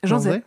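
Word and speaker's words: Jean Zay: Il y a une conférence organisée par Canopée Conférences le mercredi 22 novembre sur Jean Zé. Ça se prononce comme ça ? – Jean Zé, oui.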